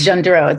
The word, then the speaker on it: Gendreau: So it's Gendreau. It's a French name.